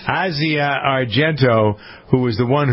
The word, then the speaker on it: Asia: It looks like Asia, but it's Asia.